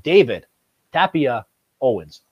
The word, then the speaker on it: David Tapia-Owens: All the way from Spain out here, we have David Tapia Owens.